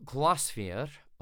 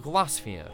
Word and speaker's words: Glaisvair: The album's called Glasvier, or at least that's how I've been pronouncing it. It's spelt G-L-A-I-S-V-A-I-R,